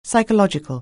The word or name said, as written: psychological